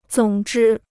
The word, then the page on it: 总之: zǒng zhī